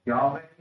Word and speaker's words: Yahweh: And as we've seen in previous weeks, capital L-O-R-D in the Bible is Hebrew for Yahweh, we pronounce, meaning I am who I am.